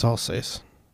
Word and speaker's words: Alsace: Alsace. – It's Alsace.